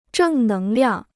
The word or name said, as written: 正能量